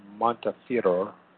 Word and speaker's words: Montefiore: This is how historian Simon Sebag Montefiore, that's how you pronounce it, the scion of the elite British Jewish family, describes the scene during the Holodomor.